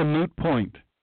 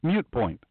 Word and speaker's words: moot point: That's a moot point. That's correct. Moot point is correct. You know what's not correct? Mute point. That's a mute point. No. So many people say it wrong.